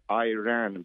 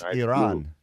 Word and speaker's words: Iran: And it's not Iran, because I didn't run. – It's Iran. Iran.